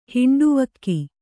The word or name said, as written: ಹಿಂಡುವಕ್ಕಿ